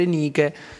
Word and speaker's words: Nike: è Nike.